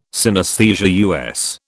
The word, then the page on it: synesthesia: British English